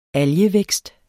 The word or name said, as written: algevækst